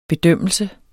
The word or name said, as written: bedømmelse